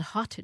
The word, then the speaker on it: Haute: It was called Haute Dish, like spelled like haute couture, and that was a wonderful restaurant, but that closed.